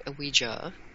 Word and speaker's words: Ouija: Yeah, let's get started by first saying that the Ouija board, Ouija, that's how it's pronounced,